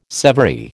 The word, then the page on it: severy: American English